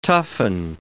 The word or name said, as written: toughen